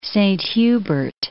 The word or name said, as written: Saint-Hubert